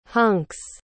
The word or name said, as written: hunk's